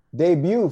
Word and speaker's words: debut: Debut, friends. Debut. The T is silent, my brother.